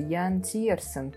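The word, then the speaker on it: Yann Tiersen: А, как правильно произносится ты, боюсь ошибиться? Ян Тирсен, по-моему. – Да.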